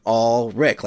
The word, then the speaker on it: Alrik: People get my name wrong all the time, and I say it's like Alrik, like all the Ricks.